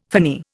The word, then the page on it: finny: Indian